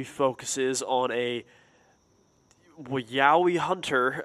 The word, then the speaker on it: Yowie: So if you're not familiar, the Yaoi, which is spelled Y-O-W-I-E, this is pretty much an Australian Bigfoot.